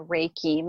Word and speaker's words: Reiki: So I also noticed in your notes, I think online, it said, and I'm going to say this wrong, but you're a Reiki master? – Yeah, I'm a Reiki master. Reiki.